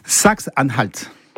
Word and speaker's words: Saxe-Anhalt: Saxe-Anhalt.